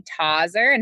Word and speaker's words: Tauzer: You know, it just depends on who you ask. Our family's huge, and half of them say Tauzer and half of them say Tauzer.